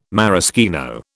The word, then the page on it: maraschino: British English